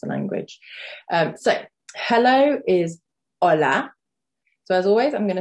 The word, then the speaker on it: hola: If you have done the phonics lesson, you will know that the H is silent at the beginning of the word hola. H is always silent at the beginning of Spanish words.